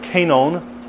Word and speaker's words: kainon: The word new here is the Greek word kainon. That's actually how you pronounce that, which is a version of the word kainos, which means new in quality or different from character.